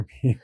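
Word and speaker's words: pipi: Would you like some ice in your pee-pee? – Oh, yeah. No, but they pronounce it pee-pee. – Yes, it's P-I-P. I just want to make everyone clear it's P-I-P-I.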